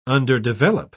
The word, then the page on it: underdevelop: British English